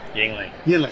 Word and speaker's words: Yuengling: Yuengling.